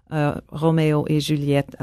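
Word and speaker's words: Roméo et Juliette: He's doing Berlioz, Romeo et Juliette. I'm giving you the French pronunciation.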